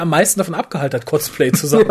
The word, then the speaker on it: Cosplay: Cosplay. Mhm. Das O wird nicht langgezogen. Und glaub mir, ich muss es wissen. Smiley. Ich habe das Wort erfunden. Es wird englisch ausgesprochen. Cos, wie Costume und eben Play.